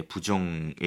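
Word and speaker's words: 부 (不): 네, 부정의 의미죠. 그 다음에 원래 사성인데 사성 앞에서는 이성으로 부드럽게 부, 짜이 이렇게 발음해 줍니다.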